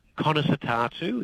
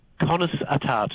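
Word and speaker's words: Conas atá tú: Conas atartu is how you say that in Irish. – Conas, say that again. – Conas atartu.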